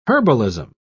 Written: herbalism

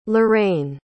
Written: Lorraine

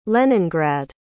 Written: leningrad

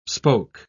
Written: spoke

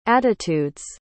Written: attitude's

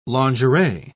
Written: lingerie

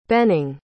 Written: Benning

